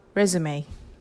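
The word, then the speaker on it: résumé: 今日の英語はレズメ、日本語で言うところのレジュメってやつですかね。日本語で発音するのと英語の発音がかなり違う言葉なので、ちょっと今日は取り上げてみました。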